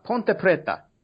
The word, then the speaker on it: Ponte Preta: Ponte Preta.